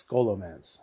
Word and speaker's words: Scholomance: So like if you do like sometimes they do these time walking events where you go back and do old dungeons, and Scholomance is there with the or Scholomance, however you pronounce it. I think it's Scholomance. That's how you pronounce it.